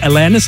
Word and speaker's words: Alanis: Na gut, technisch gesehen spricht man sie Alanis aus.